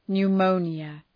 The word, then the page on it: pneumonia: nu:’məʋnjə